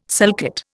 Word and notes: sulcate: Indian

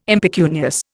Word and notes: impecunious: Indian

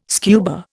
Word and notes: scuba: Indian

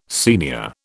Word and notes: Sr: British English

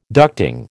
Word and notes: ducting: American English

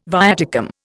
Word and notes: viaticum: Indian

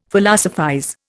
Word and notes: Philosophize: Indian

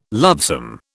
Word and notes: lovesome: British English

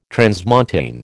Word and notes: transmontane: American English

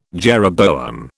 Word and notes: jeroboam: British English

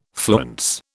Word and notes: fluence: British English

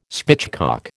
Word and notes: spitchcock: American English

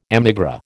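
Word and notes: émigré: American English

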